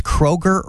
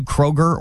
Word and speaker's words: Kroger: People around here cannot pronounce Kroger or Meijer correctly. – No, and it drives me nuts. – They put an S on it and there's no S there.